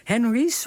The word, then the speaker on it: HENRY: Ja, Henry's, want ik moet het op zijn Engels uitspreken. Begrijp ik dat goed? – Ja, tenminste, je kunt het ook op zijn Frans uitspreken. Dan klinkt het heel chic.